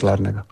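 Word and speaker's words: Larnaca: Bueno, aquí parlen els anglès i grec, i però sí, sí, els pronuncia és l'Àrnaca.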